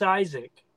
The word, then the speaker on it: Isaac: My name is Isaac, and but he's always called me IZ, and he's always signed it IZEY.